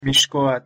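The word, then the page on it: mixcoatl: Nahuatl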